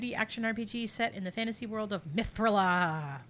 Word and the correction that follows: Mithrilia: Mithrilia. – Oh, my bad. – Yeah, gotta get that extra syllable.